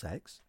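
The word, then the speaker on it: CeX: The Computer Exchange is pronounced sex.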